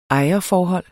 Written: ejerforhold

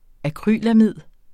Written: akrylamid

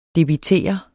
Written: debitere

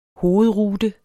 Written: hovedrute

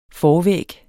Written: forvæg

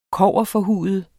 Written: kobberforhude

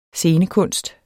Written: SCENEKUNST

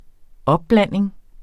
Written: opblanding

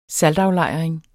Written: saltaflejring

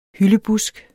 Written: hyldebusk

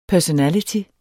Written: personality

